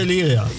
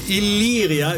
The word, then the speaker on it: Illyria: Betonen wir Illyria. – Illyria, ich habe es richtig ausgesprochen.